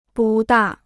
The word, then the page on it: 不大: bù dà